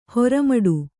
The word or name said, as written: ಹೆರ್ಮಾಡಿ